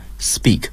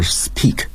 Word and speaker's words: speak: 类似的还有 speak，应该读成 speak 而不是 speak，所以大家一定要注意这一点。